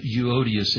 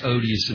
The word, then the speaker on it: Euodias: And sometimes I mispronounce their names and say, "I beseech Odious and Stinky that they be of the same mind."